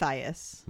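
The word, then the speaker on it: Mathias: It's Matthias. – Matthias? – I think so. – He's not like a Swedish guy? – He could be, but I had a Swedish friend named Mateus. – Yeah, I've heard most people pronounce it Matthias.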